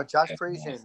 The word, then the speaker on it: Parisian: I'm, no, Josh Parisian.